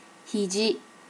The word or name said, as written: hiji